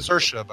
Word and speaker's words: Saoirse: Saoirse, by the way. – Is it? – Rhymes with inertia.